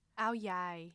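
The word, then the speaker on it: áo dài: the Ao Dai. – Ao Yai.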